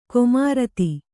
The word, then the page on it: ಕೊಮಾರತ್ತಿ: komaratti